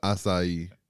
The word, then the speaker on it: acai: How do you pronounce that? It ain't a kai. – It's an acai.